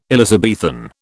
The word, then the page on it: Elizabethan: British English